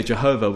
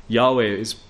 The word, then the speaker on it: Yahweh: Well, they say Jehovah, which is a wrong spelling, by the way. Yahweh is probably the closest pronunciation that we'll have.